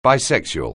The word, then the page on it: bisexual: anglais